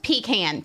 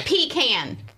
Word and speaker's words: pecan: No, it's pronounced pecan, people. Pecan.